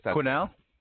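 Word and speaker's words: Quesnel: I don't even know if we have time to go through it, but Quinnell. Yeah, the S is silent.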